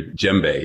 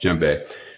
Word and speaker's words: djembe: And you're talking about your djembe. Is that how it's pronounced? The drum? – Yeah, got it exactly right. That's it. – Right, djembe.